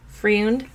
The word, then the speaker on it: Freund: It was directed by Todd Browning and I'm so sorry if I mispronounced his name. F-R-E-U-N-D. – Freund. – Freund? Okay. Yeah. – Freund, yes.